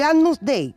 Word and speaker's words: Agnus Dei: El Agnus Dei, yo si lo estoy pronunciando mal, corrígeme. – Ahí está.